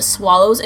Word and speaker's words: Swallows: So they ended up staying at a hotel, and they shared a hotel room at the Swallows Inn. Am I saying that right? – Mm-hmm. – To make sure. – Swallow like a bird.